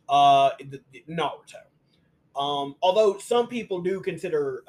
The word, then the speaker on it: Naruto: All right, we got Naruto. – Thank you for pronouncing it right, by the way. – Because if you had been like Naruto, I was gonna get up and leave.